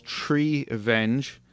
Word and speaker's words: Treevenge: it's called Treevenge, and it's spelled like it sounds Treevenge.